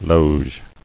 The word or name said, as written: Loge